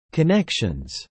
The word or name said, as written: connections